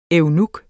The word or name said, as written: eunuk